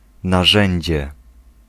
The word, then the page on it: implement: /ˈɪm.plə.mənt/